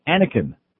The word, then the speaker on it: Anakin: Somebody has to keep an eye on their six-month-old son, Anakin. Am I pronouncing that right? – Yes.